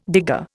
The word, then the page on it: digger: Indian